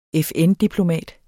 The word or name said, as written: FN-diplomat